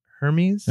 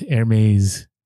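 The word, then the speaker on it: Hermès: Is it Hermes? – No, it's Hermes. – Hermes. – It's from France.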